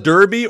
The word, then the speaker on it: Derby: So hello to everyone out there in Plymouth, Sheffield, and Derby. – Is it Derby or Darby? – Well, I think Derby is how the English would pronounce it.